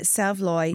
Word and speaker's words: saveloy: S-A-V-E-L-O-Y. Looks like it's spelled save loy, but you're pronouncing it savloy.